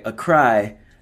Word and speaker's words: Accra: I believe it's Accra. I think I'm saying that right. Accra, Ghana.